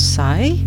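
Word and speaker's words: Sy: And the artist called Sai. It's spelled S-Y.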